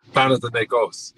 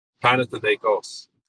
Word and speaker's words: Panathinaikos: Panathinaikos, yeah, in Greece. – Say it again. – Panathinaikos.